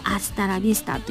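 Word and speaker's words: hasta la vista: スペイン語は H は発音しないんですよねなので HASTALAVISTA でございますそれでアスタラビスタと読みます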